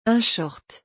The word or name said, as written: un short